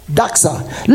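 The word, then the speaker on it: doxa (δόξα): It's pronounced doxa.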